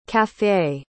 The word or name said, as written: café